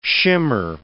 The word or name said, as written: shimmer